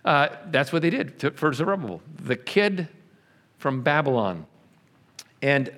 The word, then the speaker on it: Zerubbabel: Okay, now you need to know how to pronounce this name. Zerubbabel. Repeat after me. Zerubbabel. Zerubbabel.